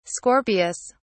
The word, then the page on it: Scorpius: British English